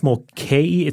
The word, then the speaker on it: Mulcahy: It's I believe it's Mulcahy. It's M-U-L-C-A-H-Y.